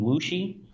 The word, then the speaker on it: Wuxi: Yeah, he lives outside of Shanghai, in Wuxi.